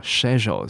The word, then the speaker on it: schedule: OK，那这个字啊，在英文我们是念成，在英式英文我们是念成 schedule，schedule。 OK，所以 schedule schedule 是不是有点差别？